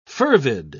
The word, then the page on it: fervid: British English